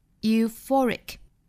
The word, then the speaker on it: euphoric: Euphoric, 重读是在 for 这个音节上。 Euphoric, euphoric.